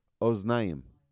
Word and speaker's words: oznayim: It's called moznayim. Moznayim are scales. What other word rhymes with that in our body? Oznayim.